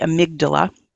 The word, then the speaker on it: amygdala: there's this whole section in your book about the amygdala, which I hope mm-hmm. I'm pronouncing correctly. – You did. You did great.